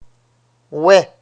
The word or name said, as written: oie